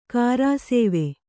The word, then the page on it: ಗುರುಸೇವೆ: kannada